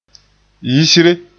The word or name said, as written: yisre